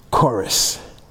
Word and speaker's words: charis: It's a Greek word, number 5485, spelled C-H-A-R-I-S. I didn't give you that at first, but that's how you spell it. C-H-A-R-I-S, chorus.